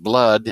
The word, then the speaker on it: BLUD: 3-inch die-cut vinyl sticker by Jonathan Fisher, and it's for a product spelled B-L-U-D. And I imagine that's pronounced blood, and it says drink it up.